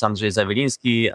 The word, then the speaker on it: Andrzej Zawilinski: Hello, my name is Andrzej Zawilinski.